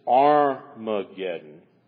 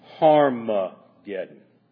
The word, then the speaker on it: Harmageddon: In fact, we have to decide whether it is Armageddon, which would mean City of Megiddo, which was really insignificant, destroyed in 350 BC, or whether it should be pronounced Harmageddon.